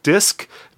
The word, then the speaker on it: DISQ: So again, the band is called Disc. D-I-S-Q is how it's spelled.